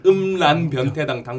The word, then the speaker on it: 음란변태당: 음란병 음란병태당도 다 가입되어 있고 음란병 아니 발음이 안 되나 음란변태당 당비병태당 음란변태당을 이제 들고 마사오닷컴으로 튀어야죠.